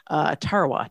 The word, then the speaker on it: Tarawa: Tarawa.